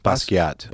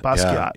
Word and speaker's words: Basquiat: Basquiat. – Basquiat.